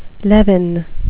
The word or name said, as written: levin